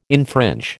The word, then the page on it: infringe: American English